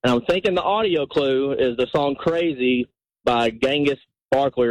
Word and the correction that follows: Gnarls Barkley: I can't really pronounce it. Gnarls Barkley.